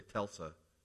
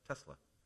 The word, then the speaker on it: Tesla: I always want to say Telsa. But it's Tesla, Elon Musk, and his fancy new truck.